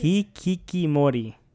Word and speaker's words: Hikikomori: It's about this Hikikomori. – Yeah, it's tough to say, man. That's good enough. – How do you say it? – Hikikomori.